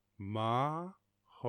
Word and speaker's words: Maharashtra: Maharashtra. Am I nailing that? I wonder if I can get a pronunciation on that. Maharashtra. Okay, let's get a pronunciation. Google, give me that. Maharashtra. Oh, I nailed it.